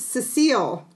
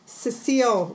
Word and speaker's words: Cecile: It's Cecile Richards. – Okay. – I keep Cecil, I believe, is the male pronunciation of that name. Cecile Richards.